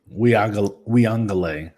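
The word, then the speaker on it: Uiagalelei: Because, like, right now we're seeing from the small sample sizes of Young and I call him Ugalele. I'm pretty sure that's not how you say it. I know you say it the correct way. I don't. – Weungale. – There we go.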